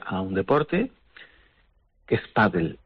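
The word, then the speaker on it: pádel: Nosotros podemos coger esa palabra que es P-A-D-D-L-E y decir, ¿cómo pronuncio yo eso? Paddle. Pues lo escribo paddle, con la tilde en la A incluida, porque es una palabra grave terminada en L.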